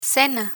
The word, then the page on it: cena: spanish